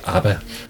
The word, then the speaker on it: ABBA: Jo, må jeg høre, hvordan det skal udtales? – Det skal udtales ABBA.